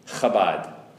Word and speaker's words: Chabad: Spelled with that, pronounced with that ch sound. Chabad.